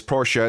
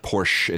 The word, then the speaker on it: Porsche: It is Porsche. It's German. Do it. It's not Porsche. It's Porsche. Trust me.